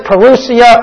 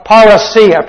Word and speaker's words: parousia: The word is parousia or parousia. Probably parousia is the better pronunciation, but parousia is what you'll hear in many circles. Both, I guess, are acceptable.